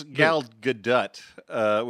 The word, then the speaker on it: Gadot: Apparently, it's Gal Gadot, which seems wrong, but.